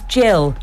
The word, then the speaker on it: Jill: Not Gil Lever. Oh. But I'm Nigerian friends and always kind of introduce me like that, so you're paying me back for my mispronunciation of Nigerian names. But yes, it's Jill.